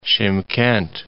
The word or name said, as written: Shymkent